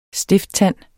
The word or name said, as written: stifttand